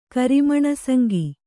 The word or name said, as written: ಕರಿಮಣಸಂಗಿ